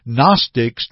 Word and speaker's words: Gnostic: Now, a quick review of the Gnostic teaching. And the word Gnostic is spelled with a G, G-N-O-S-T-I-C-S. Gnostics, the G is silent.